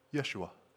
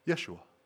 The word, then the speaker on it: Yeshua: Do you know that the Hebrew word there that's translated salvation, the Hebrew word there is pronounced Yeshua. Yeshua.